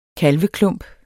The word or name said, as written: kalveklump